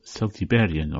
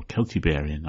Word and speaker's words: Celtiberian: Various Celtic languages were spoken in Iberia, and it's possible that they first emerged there, they first developed there, and they were known as Celtiberian or Celtiberian, I prefer to pronounce it.